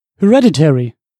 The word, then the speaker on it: Hereditary: Äh, ich picke nämlich Platz 5 Hereditary.